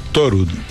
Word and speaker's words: Thorud: Thorud. Como el dios Thor, porque es un apellido noruego.